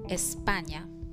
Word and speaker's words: España: In Spanish, we pronounce Spain as España. España. España.